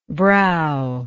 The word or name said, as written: browless